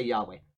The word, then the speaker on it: Jehovah: It's not Jehovah because there's no J in the Hebrew, all right? The J is the Germanic brought into our language, okay? It goes from the Germanic to the English where they actually pronounce the J. The Germans don't. They pronounce it as a Y.